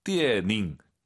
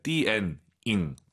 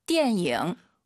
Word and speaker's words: 电影: 자, 띠에닝, 띠에닝 절대 안 됩니다. 연음, 니음 발음 들리면 안 돼요. 띠엔잉 들어볼게요. – 电影.